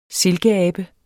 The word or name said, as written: silkeabe